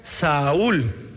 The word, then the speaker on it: Saúl: Saulo era un nombre hebreo. Se pronuncia Saúl.